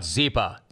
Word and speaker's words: Zyppah: I have got to tell you about Zepa. Zepa, it's spelled Z-Y-P-P-A-H.